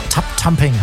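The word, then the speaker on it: Tubthumping: Chumbawamba hier nochmal kommen an einer Radioshow vorher mit Tap Tamping. Hab ich's mal endlich mal richtig ausgesprochen, oder? – Ja, voll. Tap Tamping, egal.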